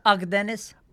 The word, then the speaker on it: Akdeniz: Akdeniz.